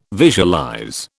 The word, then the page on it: visualize: British English